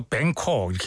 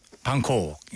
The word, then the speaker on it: Bangkok: 뭐 미국 친구들은 이거 뱅콕 이렇게 발음하자면 우린 방콕.